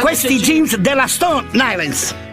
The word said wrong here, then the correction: Stone Island: Stone Island. – Ma perché scrivono come si legge? – Si chiama così, Stone Island.